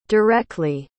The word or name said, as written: direcly